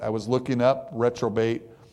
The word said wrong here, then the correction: reprobate: the definition of it. Reprobate. I'm sorry. I think I said it wrong.